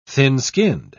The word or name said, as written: thin-skinned